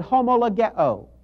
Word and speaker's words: homologeo: It's the Greek word homologeo. I'm going to spell that for you. H-O-M-O-L-O-G-E-O. That's spelled H-O-M-O-L-O-G-E-O.